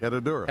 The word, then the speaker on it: Herradura: Herradura. Drink I like how you said it. I don't say it as well as you say it. You say it again.